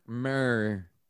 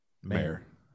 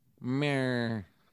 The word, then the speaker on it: Maher: would you pronounce this? M-A-H-E-R. – Mayor. – Mayor. Sean Mayor. – It's pretty common. Spelling. – Yeah, it's like John Mayer.